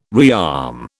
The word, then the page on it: rearm: British English